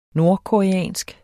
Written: nordkoreansk

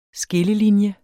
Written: SKILLELINJES

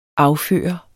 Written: afførende

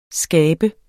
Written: skabe

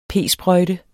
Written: p-sprøjte

